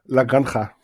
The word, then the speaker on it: La Granja: So you pronounce La Granja, La Granja, yeah. – Yeah, the right pronunciation of the game is La Granja. – La Granja. – La Granja. But La Granja. To a German tongue, this is very complicated. And in order to not break it, I'm always using the German J, La Granja.